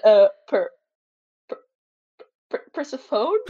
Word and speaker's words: Persephone: Persephone, yeah. Persephone.